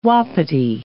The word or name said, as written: wapiti